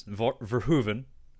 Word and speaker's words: Verhoeven: But he, of course, did Paul Verhoeven's Verhoeven.